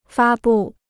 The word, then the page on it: 发布: fā bù